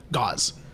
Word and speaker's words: Gausman: I am thrilled to be extremely wrong about Kevin Gausman. What would we settle on was the right way to say it? Gauze.